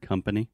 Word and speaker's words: company: And also, could you pronounce the word company? – Company? – Yes. This is very similar to discover so this is the schwa sound, Usundu Schwa Company.